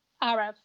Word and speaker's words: Arav: So the main character of Quiet in Her Bones and the person you write from is Arav. Is that how you say his name? – Arav, yeah. – Arav.